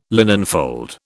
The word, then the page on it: linenfold: British English